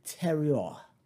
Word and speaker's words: terroir: No, it's more like terroir. I think it's kind of spelled like terroir. Terroir. It's T-E-R-R-O-I-R. I know the two R's are kind of fluid. And then the O-I-R, it's oi. Terroir. O-I-R.